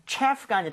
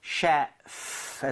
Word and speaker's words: chef: 주방장, 요리사라고 얘기를 할때 셰프가 아니라 셰프. – chef입니다. chef. f는 입술 안 붙는다고 했으니까 셰프 하시면 안 되겠죠? – 네. – so chef 해서 발음이 나와야 됩니다.